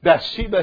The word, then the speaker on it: Bathsheba: Then David comforted Bathsheba and his wife. I'm sorry, that was not, I put an extra syllable in there. David comforted Bathsheba, his wife, and went into her and lay with her.